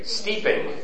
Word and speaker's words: steeping: Seeping? Am I saying that right? – Steeping.